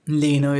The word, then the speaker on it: Lanier: Oh, this is why Lanier is the right pronunciation.